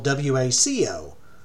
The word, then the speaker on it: Hueco: And just to be clear, Waco, Texas is spelled W A C O. When we're talking about Hueco Tanks, it has more of a hua sound to it. It's H U E C O and that's pronounced Waco.